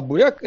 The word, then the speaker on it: буряк: То есть свекла это по-русски, а буряк это вот то, что... – Мне тоже так казалось. – Бурак так произносят в Белоруссии, значит, а на Украине буряк мягко через «я».